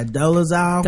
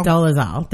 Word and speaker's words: Dolezal: Yeah, Dolezal. – Dolezal.